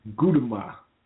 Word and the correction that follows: Gudetama: No, I said that wrong. Gudetama.